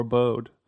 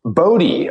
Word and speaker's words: Bode: Oh, speaking of, is my somebody was asking me, is it pronounced Bodhi or Bode? – Wow, you simps don't know. – Can you clear the air for everyone listening? There's probably some simps listening right now who don't know. – Bodhi, of course.